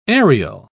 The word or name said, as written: ariel